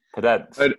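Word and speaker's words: Podence: But yeah, how do you say his name? – Pedence.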